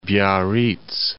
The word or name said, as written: Biarritz